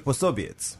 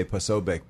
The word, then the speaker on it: Posobiec: So I think, as well as I'm here with my Hungarian friend, we can go with the official Polish pronunciation. In English, we usually say Posobiec, but yeah, for Polish, it would be Pособiec.